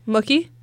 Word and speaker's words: Mookie: Mookie Betts? How does one pronounce that? – Mookie? – Like cookie.